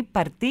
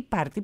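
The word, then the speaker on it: Parti: Το νέο κόμμα λοιπόν, αυτό το γενή παρτί ή παρτί, πώς το προφέρουν οι Τούρκοι? – Παρτί. – Παρτί, καλά το φαντάστηκα, ναι, καλά το φαντάστηκα.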